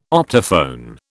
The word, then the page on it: optophone: British English